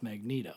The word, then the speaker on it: Magneto: Well, you would think it would be Magneto, but it's not. It's pronounced Magneto.